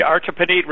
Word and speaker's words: Artibonite: Artibonite. I don't know how to say that. River, is that the correct pronunciation? – The Artibonite River is correct.